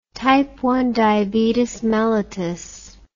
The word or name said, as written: type I diabetes mellitus